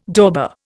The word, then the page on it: dauber: Indian